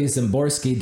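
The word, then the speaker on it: Szymborski: Ironically, Zimborski, Dan Zimborski, it's named after Dan Zimborski, whose last name actually starts with an S, but it's pronounced with a Z.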